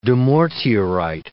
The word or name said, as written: dumortierite